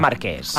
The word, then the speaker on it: Marqués: Com es pronuncia? – Marqués. – Ah, Marqués. Jo feia aquí, com si fos així.